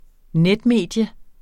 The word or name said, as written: netmedie